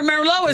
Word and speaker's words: Merlot: Yeah, remember Merlot is, and that T is silent the T on the end of Merlot.